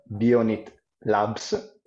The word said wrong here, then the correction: BionIT Labs: Bionic Labs sarebbe, diciamo, la pronuncia corretta, però in realtà poi cambia poco.